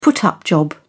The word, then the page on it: put up job: British English